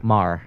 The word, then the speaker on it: Maher: Say his name again. – Marr. – M-A-H-E-R. – Yeah, like Bill Marr.